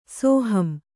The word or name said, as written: ಸೋಹಂ